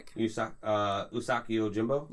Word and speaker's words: Usagi Yojimbo: Usagi Yojimbo? – Yes, thank you.